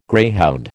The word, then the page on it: greyhound: American English